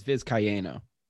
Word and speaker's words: Vizcaino: Yankees acquire Anthony Rizzo for Alexander Vizcaino. I think I pronounced that right. – I think it's Vizcaino.